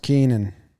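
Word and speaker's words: Keynan: I mean, I've always fancied Kenyon Middleton's game. – Yep. – Pronounced Keenan.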